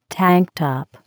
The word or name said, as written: tank top